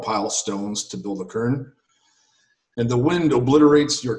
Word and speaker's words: cairn: And it's the C-A-I-R-N. So it's pronounced as K-E-R-N, like a kern.